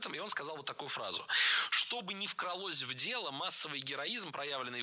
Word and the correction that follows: вкралось: Не «вкралось», а «вкралось». Ударным должен быть первый слог.